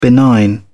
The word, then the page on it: benign: British English